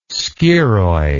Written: scirrhoid

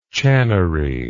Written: channery

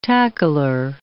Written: tackler

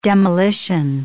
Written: demolition

